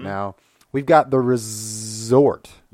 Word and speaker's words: Rezort: And this week, we are talking about The Resort. Emphasizing the midsection because it's spelled with a Z. – A Z, not an S.